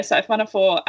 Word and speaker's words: siphonophore: Okay, it's a siphonophore, is that how you say it? – Yeah, siphonophore.